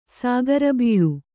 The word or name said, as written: ಸಕ್ಕರೆಬಿಲ್ಲ